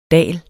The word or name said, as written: DALENE